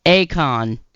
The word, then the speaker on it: Akon: Is it Akon or Akon? Akon. Okay. I always say it wrong because of the way the computer says it.